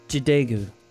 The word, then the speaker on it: Jadagu: J-A-D-A-G-U. How would you say that? – Djagu? – Jidago.